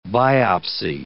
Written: biopsy

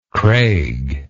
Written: craig

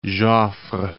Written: Joffre

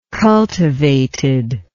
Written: cultivated

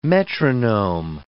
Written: metronome

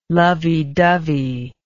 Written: lovey dovey

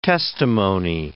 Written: testimony

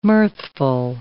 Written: mirthful

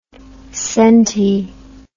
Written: centi

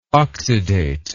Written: oxidate

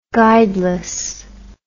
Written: guideless

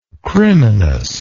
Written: criminous

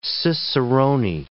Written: ciceroni

